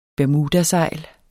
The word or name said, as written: bermudasejl